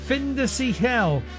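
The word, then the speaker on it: Fin de Siècle: Findecicle. That's F-I-N-D-E-S-I-E-C-L-E.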